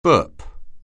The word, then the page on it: burp: anglais